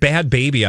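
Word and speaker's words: Bhad Bhabie: How do how do you get Bad Baby out of Bahad Bahabi? – Well, you just have to know. Oh, The H is silent.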